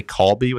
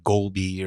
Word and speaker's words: galbi: Some people say kalbi with a K. – I haven't heard that either. – What have you heard? Galbi? – Yeah, I thought it was like golbi or...